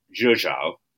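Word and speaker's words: Rzeszów: Yeah, so I flew Ryanair to a place called, I can never pronounce this right, Zhuzhou. Uh-huh. That's not how it's spelled, but that apparently is how it's pronounced, which is the closest airport to the Ukrainian border, because you can't fly to Ukraine because it's just the military.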